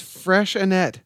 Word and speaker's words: Freixenet: It's called, pronounced Freixenet.